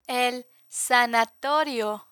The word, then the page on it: el sanatorio: spanish